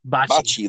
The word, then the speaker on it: Bakke: Is that how you say his name? – Bakke? – Bakke, like bocce ball? – Yep.